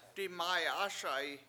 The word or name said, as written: dimAyAASa7i